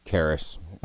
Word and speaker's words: Charis: Am I saying it right? Charis? – Charis. – Oh, there you go.